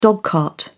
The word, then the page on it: dogcart: British English